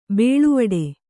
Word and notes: ಬೇಳುವಡೆ: bēḷuvaḍe

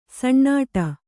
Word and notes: ಸಣ್ಣಾಟ: saṇṇāṭa; Kannada

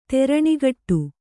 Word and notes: ತೆರಣಿಗಟ್ಟು: teraṇigaṭṭu

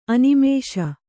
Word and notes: ಅನಿಮಿಷ: Kannada

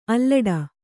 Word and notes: ಅಲ್ಲಡ: allaḍa; Kannada

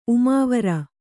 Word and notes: ಉಮಾವರ: kannada

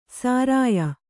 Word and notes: ಸಾರಾಯ: sārāya

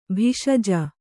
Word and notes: ಭೇಷಜ: bhēṣaja

